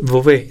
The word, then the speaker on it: Bobé: La siguiente obra que te propongo que escuchemos se llama Bobe No sé si se pronuncia así. A ver, dilo tú. Bobé, sí, Bobé, eh, más bien.